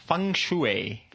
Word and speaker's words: feng shui: Uh, but before we get anywhere, you were mentioning just before we started something about feng shui. Feng, feng, feng shui.